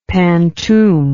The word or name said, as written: pantoum